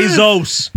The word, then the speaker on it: Bezos: Yeah. Bezos. Get his name right.